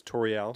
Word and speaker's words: Toriel: Am I saying that right? Or Toriel? – It's Toriel.